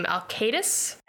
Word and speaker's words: Alkaitis: I pronounce his last name Alcatus. – And it sounds like Alka-Seltzer.